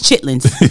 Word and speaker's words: chitlins: And I'm gonna pronounce it the correct way. Chitterlings. – Chitterlings. – Chitterlings. – No, it's chitlins. See, that's what the bougie people do.